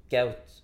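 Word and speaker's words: Kuyt: Men jag måste bara tillägga, för att uttala Kaut ordentligt så ska man nästan skära av K-t också. Kaut.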